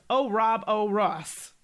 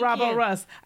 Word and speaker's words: Ouroboros: Easy to pronounce. O-Rob-O-Ross. – Ah, thank you. Thank Ouroboros.